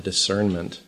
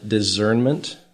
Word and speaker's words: discernment: And you'll note that I am pronouncing it with an S sound, discernment, not the Z sound, discernment.